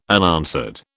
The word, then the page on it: unanswered: British English